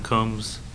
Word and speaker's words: Coombs: My name is Kevin Coombs. Last name is spelled C-O-O-M-B-S.